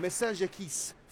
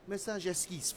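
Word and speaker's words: Messagesquisse: So this next piece of Pierre Boulez we're going to hear then, Message Esquisse for seven cellos. Let me say that properly. Message Esquisse for seven cellos.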